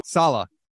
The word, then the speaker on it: Salah: Is it Salah or Salah? – Salah.